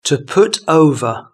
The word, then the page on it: to put over: British English